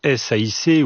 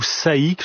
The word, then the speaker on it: SAIC: Vous évoquiez à l'instant SAIC ou SAIC. asiatique, selon les prononciations choisies par les spécialistes auto.